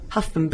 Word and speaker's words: Hougham: Oh, it's a silly surname, Huffam, but it's spelled H-O-U, isn't it? – H-O-U-G-H-A-M.